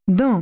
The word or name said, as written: toot